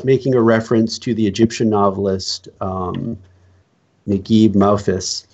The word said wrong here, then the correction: Mahfouz: Let's see here. – Josh, how would you pronounce that, by the way? – Mahfouz. – Mahfouz. Oh, okay.